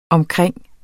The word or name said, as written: omkring